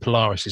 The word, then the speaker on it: Polaris: Polaris. Is that how you say it from X-Men? – Yeah, I wasn't sure who it was, to be honest with you, but Polaris is how you say it.